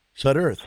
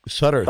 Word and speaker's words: Sudderth: My last name is Sudderth. So don't forget the th on the end. – The th. – Am I pronouncing you wrong? Your name wrong? – No, you got the Sudder, right? It's the th on the end. Sudderth.